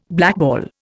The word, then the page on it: blackball: Indian